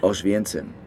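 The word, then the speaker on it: Oświęcim: Wie heißt es auf Polnisch? Auschwienzen. Äh, wie schreibt man das? O-S-W-I-E-C-I-M.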